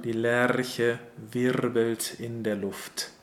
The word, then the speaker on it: Luft: We have L-U-F-T and R-U-F-T. – Yeah, but they are pronounced differently. In Luft, we have a short, opened U sound, O, Luft. And in Ruft, this comes from Rufen, from the word Rufen, where it's a long and closed U sound, Rufen.